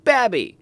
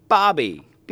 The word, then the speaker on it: Babbie: The community now lies within the city limits of Babby, or Bobby. B-A-B-B-I-E. If you're from Alabama, I'm sure you pronounce it one of those ways, or maybe not.